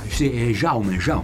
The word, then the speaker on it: Jaume: Jaume Collet-Serra. Altra uscita della scorsa settimana. No. Si chiamano Jaume. Le pronunce catalane sono importanti.